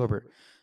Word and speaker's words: Herbert: Herbert, am I saying it right? Right, Khalil, Khalil Herbert.